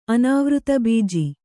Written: ಅನಾವೃತಬೀಜಿ

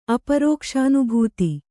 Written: ಅಪರೋಕ್ಷಾನುಭೂತಿ

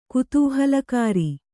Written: ಕುತೂಹಲಕಾರಿ

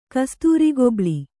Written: ಕಸ್ತೂರಿಗೊಬ್ಳಿ

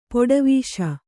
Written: ಪಡಿವೆಸ

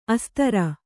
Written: ಅಸ್ತ್ರಿ

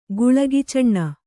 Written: ಗುಳಗಿಚೊಣ್ಣ

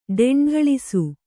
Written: ಡೆಂಢಳಿಸು

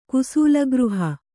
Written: ಕುಸೂಲಗೃಹ

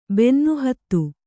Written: ಬೆನ್ನುಹತ್ತು

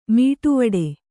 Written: ಮೀಟುವಡೆ

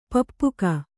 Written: ಪಪ್ಪುಕ

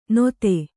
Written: ನೊತೆ

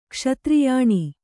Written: ಕ್ಷತ್ರಿಯಾಣಿ

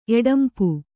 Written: ಎಡಂಪು

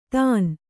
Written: ತಣ್ಣೆ

